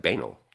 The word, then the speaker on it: banal: I mean, because it is spelt like banal, like anal with a B.